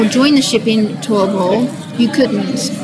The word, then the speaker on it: Turbo: For example, in a place called Turbo, T-U-R-B-O, Turbo, it's pronounced Turbo, which is based in Colombia, it's too dangerous for the ships to actually dock by the harbour.